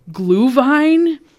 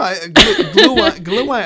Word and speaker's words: Glühwein: At the evening one, the 5 to 8, we will be offering Up North Brewing Company beer, and I'm going to butcher this, Glühwein? Mm. It's some German mulled wine. I don't know. – Yeah, yeah. No, Glühwein, I think okay. You got pretty close to it. Okay. You nailed it pretty good.